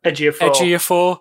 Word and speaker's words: Ejiofor: Chiwetel Ejiofor.